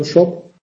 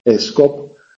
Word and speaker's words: scop: El shop está al centro, ¿entendés lo que estoy diciendo con shop? S-C-O-P, que tenéis muchas ganas de pronunciar, escop, es shop, suena exactamente lo mismo que tienda. S-C se pronuncia sh en anglosajón.